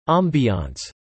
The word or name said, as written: ambiance